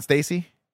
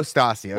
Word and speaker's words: Stassi: Stassi. Not Stacy? – No. – It's like Natasha. – Oh, Stassi.